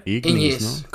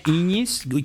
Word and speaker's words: Ignis: Ignis, como, en, como Ñ. – Como Ñ, Ignis, que, aquí Ángel nos va a caer el chahuizle con nuestra pronunciación de latín, ¿eh?